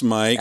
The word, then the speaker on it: Myq: Spells his name kind of weird. M-Y-Q. – Yep. Sorry about that. – Still pronounce Mike.